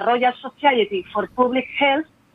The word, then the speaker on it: Royal Society for Public Health: Y es que según un estudio del 2017 de la Royal Society for Public Health, no sé si lo he pronunciado bien.